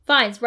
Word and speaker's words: Fiennes: Fiennes.